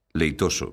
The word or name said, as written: leitoso